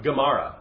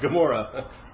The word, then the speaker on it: Gemara: I don't know if I'm pronouncing it right, but Gemara, not Gomorrah.